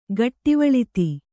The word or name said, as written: ಘಟ್ಟಿವಳ್ತಿ